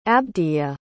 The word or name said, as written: abdhija